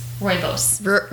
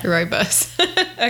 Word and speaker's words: Rooibos: I figure I'd go with that because they're the ones that, you know, they grow it, so they get to decide how it's pronounced. – Rooibos. – Yep. – Rooibos.